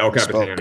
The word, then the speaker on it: El Capitan: Yeah, El Capitan.